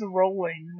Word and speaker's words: Rowling: And I was like, you're right, it's Rowling. Like, R-O-L-L-I-N-G, Rowling. Joe Rowling. – Derp on our parts. – Yep. That's okay. – We'll pronounce it correctly from now on. – Specifically, she says that Americans tend to pronounce it that way. Of course. – And it's because of how it's spelled. It's R-A-W-L, like, Rawl.